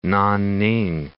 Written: Nanning

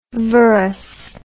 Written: vorous

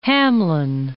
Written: Hamlin

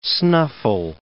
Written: snuffle

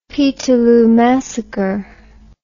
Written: peterloo massacre

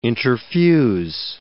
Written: interfuse

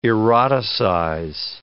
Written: eroticize

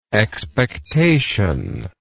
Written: expectationist

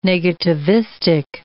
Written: negativistic

